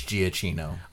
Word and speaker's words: Giacchino: however you pronounce it, Giacchino or whatever. – I believe it's Giacchino.